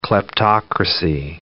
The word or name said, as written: kleptocracy